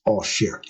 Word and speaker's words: Auxerre: Well, what is Auxerre? A-U-X-E-R-R-E, right? So that is the French pronunciation.